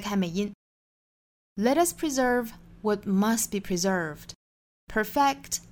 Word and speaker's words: preserve: Preserve, preserve. 注意重读音节在第二个音节 S 读 Z 的浊辅音 preserve.